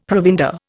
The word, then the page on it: provender: Indian